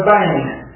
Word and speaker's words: ben: from the Hebrew word bane. And it's spelled B-E-N, the funny little designation for pronunciation.